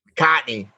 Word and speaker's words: McCartney: Am I saying it right? McCartney or McCarthy? – McCartney. Yeah.